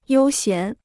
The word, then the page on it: 悠闲: yōu xián